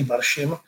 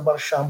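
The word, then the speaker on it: Barshim: oni mi všichni říkají Baršim, ale ta arabská výslovnost mého jména je někde mezi Baršám a Baršim, takže já jsem říkal, tak jak si přejete, abych vám říkal, říkal když mě budete říkat Baršám, budu opravdu velmi rád, protože je to eh, skutečně eh, tak, jak mé jméno zní.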